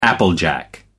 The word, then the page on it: applejack: American English